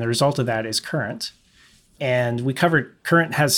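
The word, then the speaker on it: Kurent: Now, number three has a funny spelling, but it's probably going to be pronounced current. – Current. That's right. It's K-U-R-E-N-T.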